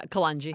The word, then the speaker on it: kalonji: Nigella seeds, or is it kalonji? I don't know how to pronounce that. – Yeah, kalonji.